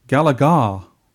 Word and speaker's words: Galaga: And they found out the correct way of pronouncing what we say Gallagher. Those guys, one of them at least, says Galaga. – It's actually Galagar because the people who, who developed the game called it Galagar.